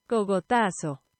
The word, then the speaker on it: cogotazo: Next up, Cogatazo. Cogatazo? Cogatazo. God, I feel like I'm struggling to get that pronounced. Cogatazo, Cogatazo. It translates to slap. Here we go. – Cogotazo. – Cogotazo. There we go. I'm just saying it weird.